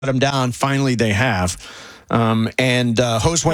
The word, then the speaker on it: Josue: Okay, pronounce that guy's first name again. – Josue. – That's how you say it. Josue.